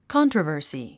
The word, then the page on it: controversy: kάntrəvə̀ːrsi; カントラバー スィ